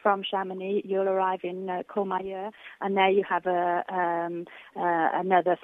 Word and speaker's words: Courmayeur: Is it Courmayeur? – That's right, yes.